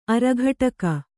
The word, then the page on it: ಅರಿಕಟಕ: arikaṭaka